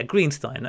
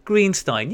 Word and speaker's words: Greenstein: Yeah, Greenstein, that's right. Hang on. He said, rhymes with free wine. So, Greenstein.